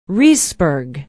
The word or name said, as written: reseburg